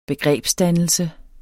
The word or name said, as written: begrebsdannelse